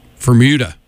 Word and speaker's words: Febueder: The band name is spelled F-E-B-U-E-D-E-R. And according to their Facebook page, it's pronounced like Bermuda, but with an F. So, Fermuda. But I don't see how they get there from the letters that are in.